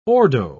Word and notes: ordo: British English

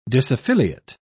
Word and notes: disaffiliate: British English

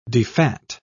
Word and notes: defat: British English

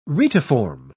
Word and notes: retiform: British English

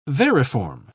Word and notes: variform: British English

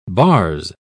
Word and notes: bars: British English

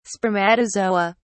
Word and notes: spermatozoa: British English

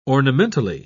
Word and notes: ornamentally: British English

